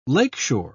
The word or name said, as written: lakeshore